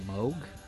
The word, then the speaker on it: Moog: We'll hear Moog all over this record, and it's great. – Actually, it's pronounced Moog.